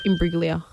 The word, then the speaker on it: Imbruglia: Soft G. – Soft G. – Imbruglia. – Soft G. – Imbruglia. – Bang. – Imbruglia. Natalie Imbruglia.